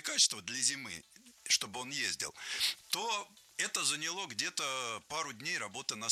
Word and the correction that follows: заняло: Нет, плохо, потому что не заняло, а заняло. Ударение не ставим на окончание ни в коем случае.